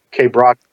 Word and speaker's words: Quebracho: I believe it's pronounced Quebracho, is that correct? The wood? – Quebracho. – Quebracho, okay.